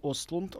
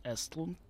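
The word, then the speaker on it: Östlund: Режиссер э, Рубин Остлунд, он же Эстлунд, так это правильно произносится.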